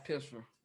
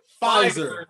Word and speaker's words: Pfizer: I have Pisfer.